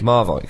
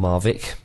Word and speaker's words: Marwijk: Marwijk. Yeah. Or Marvik, depending on if you want to be wrong or not.